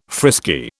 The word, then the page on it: frisky: British English